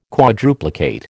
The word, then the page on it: quadruplicate: American English